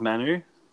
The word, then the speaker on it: Manu: Okay, Julian, next up we have the Manu tribe. I'm assuming that's how it's pronounced. – Yes. – Yeah, you're happy with Manu? – Sounds good to me.